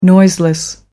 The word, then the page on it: noiseless: American English